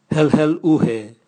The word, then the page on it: helhel uhe: Raga